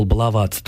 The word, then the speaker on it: баловаться: что вообще-то в норме глагол «баловаться» должен изменяться, ударение в нем ставится точно так же, как в глаголе «целоваться», в котором обычно русские люди не делают ошибки, но из-за того, что у нас есть слово «баловень», вот это вот начальное, первый ударный слог, он перетягивает в глаголе «баловаться» ударение на первый слог.